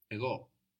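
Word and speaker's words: ego (εγώ): The word for I in Greek is ego. Ego. – Ego. – So we have a new sound here. It's like a soft G which hangs around in the throat.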